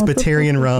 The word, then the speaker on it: Batarian: Right, because Batarian rhymes with Solarian.